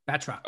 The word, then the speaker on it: Batroc: Batroc.